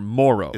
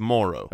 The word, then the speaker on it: Moreau: It's spelled Moreau, but no. – It's Foster Moreau. – It's Moreau. – Okay, the emphasis of the O is earlier in the right.